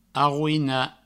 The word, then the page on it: arroinar: arrouina